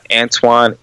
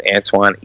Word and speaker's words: Antione: It's Antoine E. Anderson. And that's Antoine spelled A-N-T-I-O-N-E. And then E like elephant. Last name is Anderson. And the Anderson is spelled with the S-O-N. That's Antoine E.